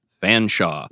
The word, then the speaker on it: Featherstonehaugh: Uh, my one of my professors was fond of telling uh, a story about a young woman in one of his classes whose name was spelt Featherstonehaugh, but pronounced Fanshawe.